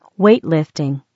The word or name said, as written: weightlifting